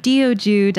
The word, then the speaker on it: Teochew: That was a great effort. You were like 90% of the way there. The Teochew Diaspora. Yeah, it's a super confusing word to pronounce because the way that you pronounce it in the local language isn't really how it's spelled phonetically.